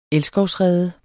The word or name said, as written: elskovsrede